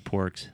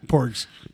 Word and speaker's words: Porgs: So you can make all the Pogs in the world that you want. So I think you mispronounced Porgs. – Porgs.